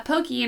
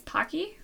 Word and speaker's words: Pocky: And he shoves her into hers, and a girl immediately sits on his lap, and she has a pokey in her mouth, or as us Americans say, I guess, pocky? – Yeah, okay. Right? Pocky. Yeah. That's how I hear it.